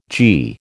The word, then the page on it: gie: American English